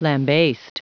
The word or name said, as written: lambast